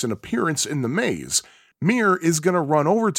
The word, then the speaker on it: Miru: It is spelled M I R U, but I'm pretty sure that it's pronounced Mir because Japanese words tend to have a silent U at the end.